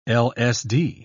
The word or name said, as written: LSD